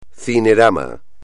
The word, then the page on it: cinerama: espagnol